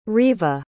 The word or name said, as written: riva